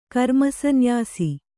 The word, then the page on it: ಕರ್ಮಸನ್ಯಾಸ: karmasanyāsa